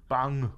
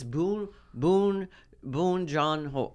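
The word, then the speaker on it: Bong Joon-ho: I looked up how to say his name. – Bang. – No, it's Boon Jong Ho.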